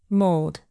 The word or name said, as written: mould